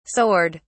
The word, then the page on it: soared: British English